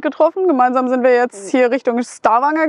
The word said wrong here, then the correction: Stavanger: gefahren wie spricht man das aus? Stavanger? Stavanger? Ja, Stavanger.